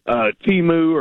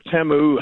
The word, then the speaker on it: Temu: If by that metric, you could say that Amazon or Temu or Temu, however you want to pronounce it, that nobody should sell anything online because they didn't spend the money for a brick and mortar store.